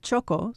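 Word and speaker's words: txoko: The word choco, spelled with a TX, means corner in the Basque language, in the sense of being a cozy, secret place.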